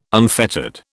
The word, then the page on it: unfettered: British English